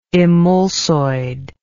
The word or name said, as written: emulsoid